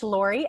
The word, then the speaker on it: Laurie: I should probably also spell my name, because I spell it L-A-U-R-I-E. you can find me at Life Coach Lori, L-A-U-R-I-E at Insta.